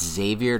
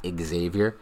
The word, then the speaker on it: Xavier: and that's it's Xavier, not Xavier. The X is not pronounced in his name,